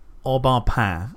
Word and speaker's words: Au Bon Pain: Au bon pain.